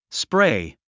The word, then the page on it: spray: spréi; スプレイ